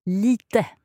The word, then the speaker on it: lite: Lite. – Lite. Okay, so, jag pratar lite svenska. – Perfect. And it's important for English speakers to remember that we always pronounce the E's on the end in Swedish. Mm-hmm. So, lite, spell L-I-T-E.